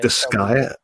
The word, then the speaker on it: Disgaea: Disgaea.